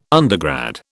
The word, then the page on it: undergrad: British English